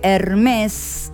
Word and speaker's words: Hermès: Hermès, se escribe Hermès con acento invertido en la E, pero se pronuncia Hermès, la casa francesa de diseño y de moda que se fundó en el año 1837.